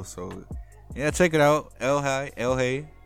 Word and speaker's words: ELHAE: I think it's pronounced Elhigh, capitals, all caps, E-L-H-A-E.